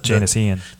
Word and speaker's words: Janis Ian: And I was like, who's Janis Ion? And so I looked up the Janis Ian. – Janis Ian, sorry. Janis Ian. See, I couldn't even say her name.